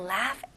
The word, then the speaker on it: laugh: 발음은 laugh. 마지막 G, H는 F 발음으로 하셔야죠.